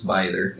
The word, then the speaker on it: Bither: I've heard her name pronounced probably four different ways but I think the the general consensus on how it is pronounced is Bither.